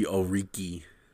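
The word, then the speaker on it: Okereke: And Okiki, Okuriki, how you say his name? I can never say I got it. you. I got you. – Bobby Oriki. Oriki and Darius Leonard, when they blitz, they just make plays, man.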